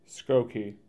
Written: Skokie

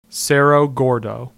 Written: Cerro Gordo

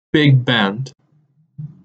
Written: Big Bend

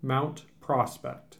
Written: Mount Prospect

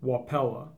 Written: Wapella

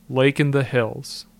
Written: Lake in the Hills

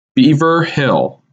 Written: Beaver Hill

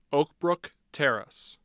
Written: oakbrook terrace

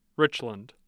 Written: Richland

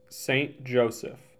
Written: St. Joseph

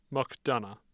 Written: McDonough

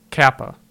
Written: Kapa

